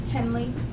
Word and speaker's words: Henley: K-E-Y-A-R-A. – We will. – And what's your last name? – Henley. H-E-N-L-E-Y.